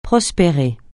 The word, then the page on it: prospérer: francais